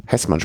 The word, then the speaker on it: Hessmann: Das Heesmann. – Hessmann spricht man es aus, okay.